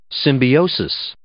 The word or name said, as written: symbiosis